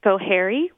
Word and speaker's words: Schoharie: It also covers Delaware, Schoharie, which I've never said that word out loud before, so I'm not sure if I pronounced that right. – I think you did.